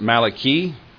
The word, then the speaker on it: Malachi: So Malachi, his name is Hebrew, pronounced Malachi, and it signifies messenger of Yahweh, or my messenger.